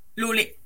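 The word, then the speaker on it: Lolë: Euh, donc on rappelle, ça, ça se prononce Loli. – Lolé. – Lolé et je vais y arriver, hein. L-O-L-E Tréma, vous l'avez forcément vu quelque part.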